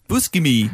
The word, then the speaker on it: Buscemi: Did anyone pronounce it the way they do in Kimmy Schmidt, which is that they say Steve Buscemi, which is one of my favourite things ever. – That's not how you say it. – Oh. Buscemi. – Buscemi. – Steve Buscemi.